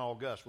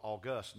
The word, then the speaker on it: august: But we're celebrating the fact that we can have an August. We're putting the emphasis on the last syllable, August, not August.